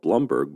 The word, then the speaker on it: Bloomberg: But I wonder why Bloomberg calls himself Blumberg, but allows his company to call him Mike Bloomberg.